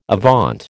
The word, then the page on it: avaunt: American English